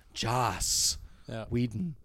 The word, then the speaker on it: Joss Whedon: And they brought in Josh Whedon. Or, I'm sorry, I got to pronounce that. Make sure I really enunciate that. Joss Whedon.